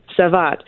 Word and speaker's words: Savate: I'm going to say this wrong. Savate? How do I say that properly? – Savate.